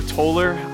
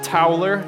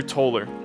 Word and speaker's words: Toller: My name is Drew Toller, not Towler, as many like to pronounce it, but it is Drew Toller.